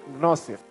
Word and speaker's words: gnosis: But in English, would read it as gnosis and it's pronounced gnosis. Gnosis. This is where we get the word diagnosis.